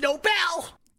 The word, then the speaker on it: Nobel: Oh, I wanted to say Nobel. See, I know it's Nobel. You thought I was going to say Nobel. It's Nobel.